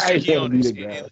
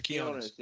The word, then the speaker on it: Quiñones: I don't know how to pronounce the last name. It looked like Quiznos. I ain't gonna lie, but I ain't want to be the guy. – Lester Quiñones. It's Lester Quiñones.